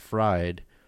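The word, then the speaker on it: Freyd: And then I found this dude, Peter J., I believe his last name is pronounced Fried.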